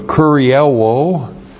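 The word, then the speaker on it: Kurielwo (κυριεύω): This is the present active indicative of kurielwo,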